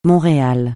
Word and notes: Montréal: francais

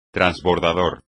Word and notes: transbordador: espagnol

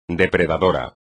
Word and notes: depredador: espagnol

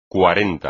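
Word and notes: cuarenta: español

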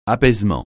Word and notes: apaisement: francais

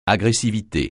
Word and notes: agressivité: French

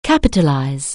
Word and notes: capitalize: anglais

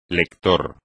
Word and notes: lector: Spanish

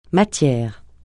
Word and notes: matière: francais

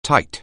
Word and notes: tight: anglais

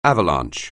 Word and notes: avalanche: anglais

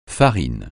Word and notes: farine: francais

